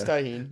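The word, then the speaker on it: Tajín: He said tajin. – Oh, look, it didn't spell it. Hold on. Am I thinking of a different thing? It's T-A-J-I-N, right? – Yeah, it's tahine. The J style.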